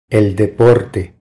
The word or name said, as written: El deporte